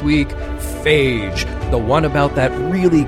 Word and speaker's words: Phage: Yeah, see, there's phage, which is the way we pronounce this episode, and then there's Fage, which is the yogurt.